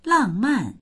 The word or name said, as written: lang4man4